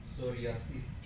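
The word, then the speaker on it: psoriasis: خودش سوریاسیس پیش تلفظ نمیشه مثل نومونیا که پنومونی نیست.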